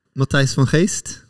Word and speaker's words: Mathijs van Geest: Vil du uttale det rett? – Mathijs van Geest. – Van Geest, ja. Så litt langere E.